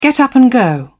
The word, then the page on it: get up and go: British English